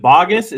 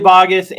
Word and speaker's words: Baugus: Is it Baugus? Is that the way to pronounce it right? – That's it. You got it. – Okay. I thought so. So Billy Baugus, and just in case you guys all don't know, Billy is he's a veteran of the detailing industry.